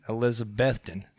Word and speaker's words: Elizabethton: Elizabethton, Tennessee.